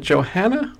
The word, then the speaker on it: Johanna: Is it Johanna or Joanna? – Johanna. And she's actually in Pennsylvania. – Oh, I did not know that. So she does pronounce the H in her name.